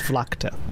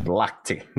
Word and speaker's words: Vlakte: The K comes before the T when you pronounce the city's name. It's Vlakte. – Vlakte.